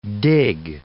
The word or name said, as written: dig